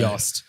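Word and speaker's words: Jost: So it's absolutely Yost. – Yes. – Yost Capito, and every other pronunciation of his name, who is enjoying retirement again, I guess.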